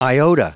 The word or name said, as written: iota